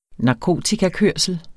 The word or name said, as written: narkotikakørsel